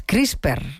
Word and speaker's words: CRISPR: Todo lo que tiene que ver con esto de las CRISPR, no sé si lo digo bien, ¿no? – Sí.